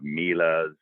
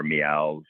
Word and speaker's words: Miele: Um, we have Miele's uh, or Mials, whatever you want to pronounce it.